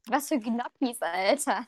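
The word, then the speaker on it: Gnocchi: die goldgelbenen Gnotten, gestampften Kartoffeln und Mehl werden eigentlich Njotokki ausgesprochen. Jotkki.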